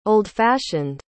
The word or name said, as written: old fashioned